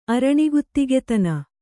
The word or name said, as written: ಅರಣಿಗುತ್ತಿಗತನ